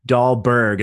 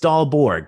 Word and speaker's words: Dahlborg: Everybody says Dahlborg. And I'm like, hey, man, that's an O at the end. It's Dahlborg. Come on.